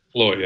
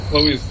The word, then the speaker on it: CLOi: Chloe, yeah. Chloe. – Oh, pardon me, but it's spelled C-L-O-I, so you'll forgive me for calling it Chloe.